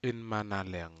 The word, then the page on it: inmanaleg: Aneityum